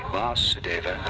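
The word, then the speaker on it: Vāsudeva: But there's a difference. The Vasudeva, the long A, Vasudeva, and there's Vasudeva. The difference.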